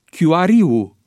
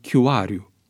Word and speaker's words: Chiuariu: Astfel se pronunță Tudor Chiuariu în loc de Tudor Chiuariu,